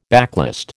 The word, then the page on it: backlist: American English